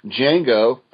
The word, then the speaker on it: Django: Anyway, based off of Tarantino's upcoming film, Django, the D is silent, Django Unchained, number 1, goes on sale.